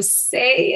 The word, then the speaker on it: Sé: I am Say Marie. So Say is my name. It's pronounced like Say Marie, but it's spelled S-E.